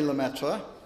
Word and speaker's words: Lemaître: Georges-Henri Lemaître, and that is the last time I will attempt the French pronunciation, was born in 1894 in Charleroi, Belgium.